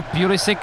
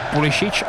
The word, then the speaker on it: Pulisic: Ono Pulisic, těžko říct, jak jeho jméno vyslovovat, protože samozřejmě má předky v Evropě na Balkáně, proto by se člověku chtělo říkat Pulisic, ale on sám si říká Pulisic, má americkou národnost a v Americe mu neřekne nikdo jinak než Pulisic.